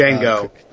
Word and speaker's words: Django: It's Django.